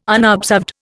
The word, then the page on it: unobserved: Indian